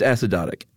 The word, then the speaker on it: acidotic: It's acidotic. – Oh, it is? – Yeah. – I actually probably would have made it a long O. – Yeah, no long O, apparently.